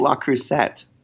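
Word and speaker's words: Le Creuset: How did you pronounce that? – Le Cruze. – How do you pronounce it? – I think it's La Crusette. – Babe, it ain't. – It may not be, but it's definitely not Le Cruze.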